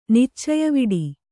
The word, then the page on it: ನಿಚ್ಚಯವಿಡಿ: kannada